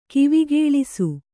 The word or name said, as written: ಕಿವಿಗೇಳಿಸು